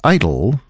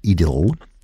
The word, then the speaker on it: idyll: An idyll, or if you prefer the English pronunciation, an idyll.